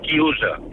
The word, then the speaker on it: chiusa: Y un momento, y quiero decirle que en italiano se dice chiusa. Coro a boca chiusa. Chiusa es otra, es una señora.